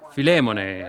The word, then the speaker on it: Filemone: Così Filemone, ha detto qualcuno là in fondo, che penso ne sia veramente la pronuncia corretta.